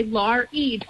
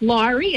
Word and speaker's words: Laurie: So we teased because Sean said, Mom, Mr. and Mrs. Trimmer are afraid to say your name because, you know, so many people say Lori instead of Laurie.